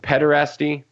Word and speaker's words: pederasty: It was about, is it pederasty? That's how you pronounce it.